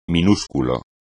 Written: minúsculo

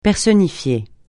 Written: personnifier

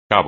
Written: cabo

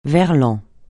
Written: verlan

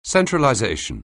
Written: centralization

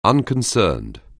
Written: unconcerned

